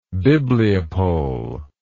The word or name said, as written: bibliopoly